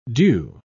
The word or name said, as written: dew